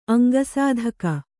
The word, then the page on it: ಅಂಗಸಾಧಕ: kannada